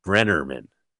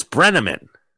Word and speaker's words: Breneman: She mispronounces Tom Breneman's name. She throws in a second R. It's very hard. Brennerman. And this must be a beef for this guy because it's in the script. And the name is Breneman and she tries it.